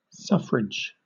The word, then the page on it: suffrage: British English